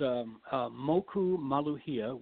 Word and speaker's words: Moku Maluhia: Yeah, it's pronounced Moku Maluhia, which means Moku is the word for island or district, and Maluhia means peace. So it translates as Peaceful Island.